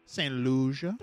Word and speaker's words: St. Lucia: St. Lucia.